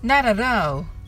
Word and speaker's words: Not at all: Not at all はアメリカ英語だと Not の t と at の t がどちらも L の発音になります。ですので、なららうとなります。